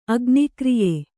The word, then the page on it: ಅಗ್ನಿಕ್ರಿಯೆ: agnikriye